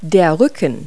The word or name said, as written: back